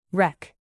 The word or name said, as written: wreck